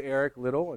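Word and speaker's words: Eric Liddell: I've been back and forth on how to pronounce it, but I heard Alistair Begg, who's Scottish, say Eric Little, and it's Eric Little himself is Scottish, so that's how you say it.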